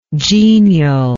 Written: genialness

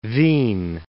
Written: Wien

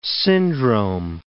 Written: syndrome

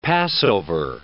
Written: Passover

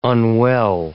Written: unwell

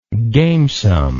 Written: gamesomeness

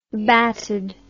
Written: batted

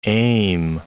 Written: aim

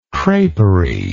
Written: creperie

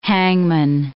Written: hangman

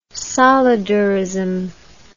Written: solidarism

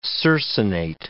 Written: circinate

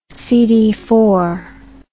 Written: CDF

